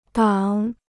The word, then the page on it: 挡: dàng